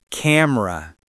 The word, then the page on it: camera: American English